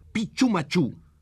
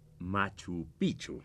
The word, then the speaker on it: Machu Picchu: Si dije Pichumachú, es que es Pichumachú. ¿Entendido? – Así es, señor Burns. – Bueno, sí, por supuesto. Pero permítame entonces aclarar que en todo caso se pronuncia Machu Picchu.